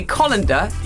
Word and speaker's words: colander: He says we are pronouncing C-O-L-A-N-D-E-R wrongly. – I've no idea how I say it. – That's what we're supposed to be using today. It's one of the suggestions that we use a colander in my world. – Yes, yes, yes. – And he says that we should say colander.